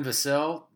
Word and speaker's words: Vassell: Devin Vassell rhymes with Sam Cassell, if you get confused with how to say the last name.